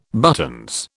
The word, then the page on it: buttons: British English